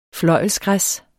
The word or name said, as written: fløjlsgræs